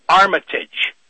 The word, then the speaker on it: Armitage: Richard Armitage, the gentleman you're talking about, who does have this history going way, way back, and his name is, it's pronounced Armitage, but it's spelled, it's, everybody pronounces it, it's, it looks and it looks to be like it's pronounced Armitage, A-R-M-I-T-A-G-E, but he pronounces it Armitage.